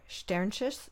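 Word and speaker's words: Sternschuss: And I believe like one of the melodies on there, Scholar Anna Sternis, no, I'm not pronouncing that right, Sternschiss. – That's right. That's right.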